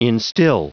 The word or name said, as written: instil